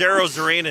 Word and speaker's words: Arozarena: Now, I'm probably going to butcher his name, but Arozarena. – It's close enough. – I love it. His last name is Arozarena.